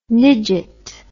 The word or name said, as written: nidget